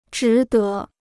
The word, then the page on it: 值得: zhí de